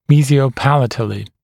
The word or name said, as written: mesiopalatally